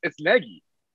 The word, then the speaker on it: Nagy: It's just like, but we found out it's Nagy. – That's right. Well, yes, I knew it was Nagy,